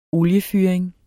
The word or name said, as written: oliefyring